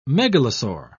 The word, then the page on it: megalosaur: British English